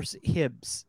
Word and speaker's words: Hibs: Do you pronounce it Ibs or Hibs? – Hibs. – Hibs. Okay.